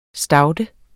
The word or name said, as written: staude